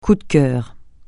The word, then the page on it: coup de cœur: francais